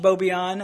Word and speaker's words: Boubion: But in the Middle East, right, my last name is French, Boubion. I don't know how to pronounce it, but I think it's like Boubio or Boubio, right? They do the hand thing too, and they say it, Boubio.